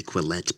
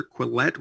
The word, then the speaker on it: Quillette: Listen to the Quillette podcast. – Just go to Apple Podcasts, Stitcher, Spotify, wherever. Just enter Quillette, which is spelled like Gillette, but Quillette.